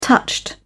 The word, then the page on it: touched: British English